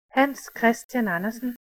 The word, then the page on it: Hans Christian Andersen: ˈhænˀs ˈkʰʁestjæn ˈɑnɐsn̩; Danish